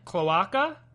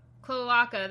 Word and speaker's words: cloaca: Or maybe I pronounced it wrong, but... – Cloaca? – Cloaca, that's what it is.